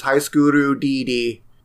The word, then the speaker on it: High School DxD: Yeah, according to Wikipedia, the Hepburn pronunciation is Haiskuru DD.